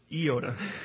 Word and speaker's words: iota: I took Greek, and the correct pronunciation is iota.